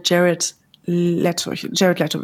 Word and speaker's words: Jared Leto: Und das, ich kann mich an einen Moment erinnern, da hatten wir Jared Leto wird er ausgesprochen, war er zu Gast.